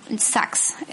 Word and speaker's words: Sachs: Y Tom Sarks, creo que es Tom Sarks. Tom Sachs, es S-A-C-H-S. Es como se escribe su nombre.